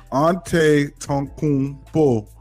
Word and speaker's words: Antetokounmpo: Three, you got Giannis Antetokounmpo, whatever his name, however you pronounce his name. – Antetokounmpo.